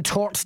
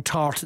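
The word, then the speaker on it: torte: A pecan tort day. – Yum. – Tort. Have I pronounced that right? It's not pecan tart, it's tort.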